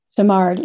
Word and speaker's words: Simard: I in the story that you told, and I may bollocks her name, Susan Simard, is that how you say it? – Simard, yeah.